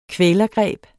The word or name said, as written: kvælergreb